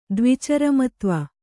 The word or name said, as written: ದ್ವಿಚರಮತ್ವ